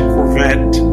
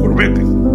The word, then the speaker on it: Corvette: Nosotros, en inglés se dice Corvette, pero en español nosotros decimos Corvette, porque así es como se escribe, ¿no? C-O-R-V-E-T-T-E, Corvette.